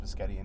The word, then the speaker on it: biscotti: I was going to say biscotti and meatballs. Like, what do you get as a side for biscotti and meatballs? Mm-hmm. And I am saying it right. It's originally pronounced biscotti in Italian.